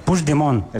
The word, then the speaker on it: Puigdemont: Puigdemont. – Puigdemont. – Vale. – Puigdemont. – Eso es. – Sí, lo dices como francés, así.